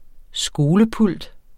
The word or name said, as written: skolepult